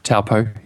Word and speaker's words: Taupo: He's listed Wellington, Gisborne, Christchurch, New Plymouth, Auckland, Taupo. Is that how you pronounce that? – Taupo, yep.